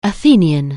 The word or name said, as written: Athenian